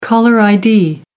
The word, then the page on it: caller id: American English